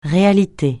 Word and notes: réalité: francais

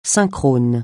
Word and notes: synchrone: francais

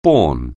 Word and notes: born: anglais

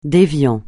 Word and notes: déviant: francais

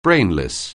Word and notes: brainless: anglais

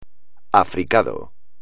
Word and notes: africado: espagnol

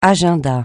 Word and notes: agenda: French

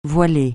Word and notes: voiler: francais

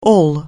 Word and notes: all: English

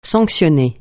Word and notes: sanctionner: francais

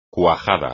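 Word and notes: cuádruple: español

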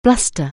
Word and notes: bluster: anglais

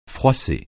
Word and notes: froisser: francais

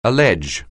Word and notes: allege: English

